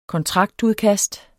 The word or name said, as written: kontraktudkast